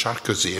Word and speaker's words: Sarkozy: By the way, his name is properly pronounced Sarkozy, and you know what it is? It's a Turkish word meaning gypsy.